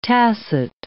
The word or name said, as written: tacit